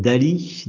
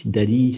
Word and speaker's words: DALL-E: DALI. DALI, si vous voulez aller jeter un œil, ça s'écrit D-A-L-L-E et, euh,